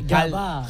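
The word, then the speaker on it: Gavà: Jardín de Oro de Gabà. – Però pronuncieu bé. – Gabà.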